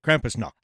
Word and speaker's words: Krampusnacht: Krampusnacht. – Did I say that right? – Yeah, you did. Krampusnacht.